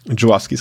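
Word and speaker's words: Żuławski: aber ja, äh, so, so ein Film wie Possession oder generell halt, ähm, äh, ähm, was müssen wir nicht falsch aussprechen, Juwawski, Jowowski ist richtig, ja.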